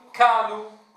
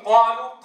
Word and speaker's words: قالوا: تعالوا لنرى بعضها، نطقها قريبة من الكاف فيقرب المخرج فيقول كالو، بدلا يقول قالوا قالوا قالوا قا قا قا، فيجعل القاف مشتركة مع الكاف.